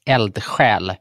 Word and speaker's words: eldsjäl: So the Swedish pronunciation is eldsjäl.